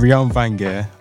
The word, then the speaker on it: Reon Vangèr: I'm Reon Vangèr.